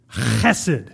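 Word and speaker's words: chesed (חסד): You're supposed to say it with this really deep guttural, like, chesed.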